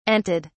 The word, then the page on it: anted: British English